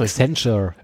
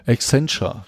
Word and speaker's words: Accenture: Ich, ich, ah, ich, kann das nie aussprechen. – Accenture. Accenture, genau. Genau.